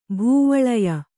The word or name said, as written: ಭೂವಳಯ